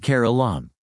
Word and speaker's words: Keralam: In Malayalam, the state has always been called Keralam, with Kara, meaning coconut tree, and Lam, meaning land.